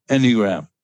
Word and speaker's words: Enneagram: Enneagram. – I think, I don't think it's Enneagram. It's Enneagram. Okay. – Enneagram. So accent on the first syllable.